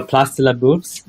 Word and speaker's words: Place de la Bourse: Oh, Place de la Bourse? – There you go.